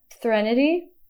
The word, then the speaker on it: Threnody: Threnody.